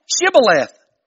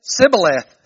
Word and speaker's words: Shibboleth: Then they said unto him, "Say now, Shibboleth." And he said, "Sibboleth." I love this. For he could not frame to pronounce it right.